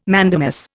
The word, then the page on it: mandamus: Indian